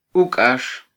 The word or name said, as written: ukax